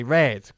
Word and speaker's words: read: They read. Como el color. Pronuncia igual. Ok, muy bien.